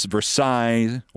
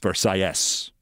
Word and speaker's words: Versailles: And yes, there are two different pronunciations. In France, it's Versailles or Versailles. In Miami, it's Versailles.